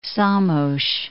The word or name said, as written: Szamos